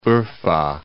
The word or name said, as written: Urfa